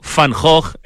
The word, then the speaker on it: Van Gogh: ¿Qué condiciones tiene Santiago eh, que permite que hayan elegido a esta ciudad para el debut de Mid Vincent Van Gogh o Van Gogh en Santiago de Chile? – Bien pronunciado.